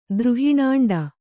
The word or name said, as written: ದ್ರುಹಿಣಾಂಡ